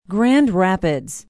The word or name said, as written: grand rapids